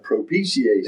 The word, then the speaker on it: propitiation: That's called propitiation.